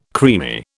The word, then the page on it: creamy: British English